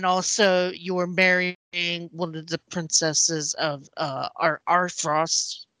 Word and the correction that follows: Asfrost: Did I say that right? – Asfrost.